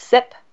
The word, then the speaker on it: CYP: That's abbreviated CYP, pronounced SIP.